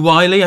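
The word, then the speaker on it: Wyllie: Now, I never know how to pronounce that. Is it Andrew Wiley, I think, of Costain? – Yes.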